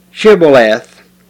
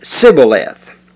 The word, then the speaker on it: Shibboleth: If he said, 'Nay,' then they said unto him, 'Say now, Shibboleth.' And he said, 'Sibboleth.' For he could not frame to pronounce it right."